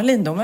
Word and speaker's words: Lindome: Men du, apropå hönor och ägg, så vet du att det är en matbutik i Lindome, uttalas det så? Lindome? – Ja, Lindome. Ja.